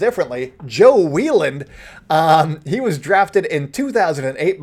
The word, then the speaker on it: Wieland: Anyways, let's talk about Joe Wieland, and that is how you say it, even though it looks like Wieland, like Scott Wieland, the Melody Man, which is a deep cut.